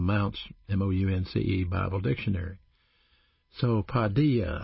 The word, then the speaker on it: paideia: The word chasten is a Greek word pronounced paideia, P-A-I-D-E-I-A, paideia.